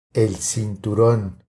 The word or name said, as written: El cinturon